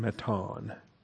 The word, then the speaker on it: Mattan: Yes, M-A-T-T-A-N, Matan. Didn't hear that. All right, we'll try again. Speakers are on the Surface dock. you need to unmute the input for pulpit audio, I think it's called. – Matan.